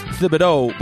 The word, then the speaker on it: Thibodeau: It's pronounced Thibodeau, but he goes by Tibbs.